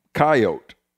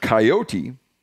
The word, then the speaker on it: coyote: Oh, This guy says, I grew up in rural area Kansas, where the word coyote was pronounced in the two syllables form, coyote. – Okay. – I would only hear the three syllable pronunciation of coyote from television or media.